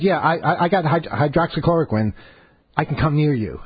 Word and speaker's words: hydroxychloroquine: hydroxychloroquine. I'll be fine, bro. Did I say it right this time? – Yeah, that was close enough. – Okay, good. Yeah, hydroxychloroquine.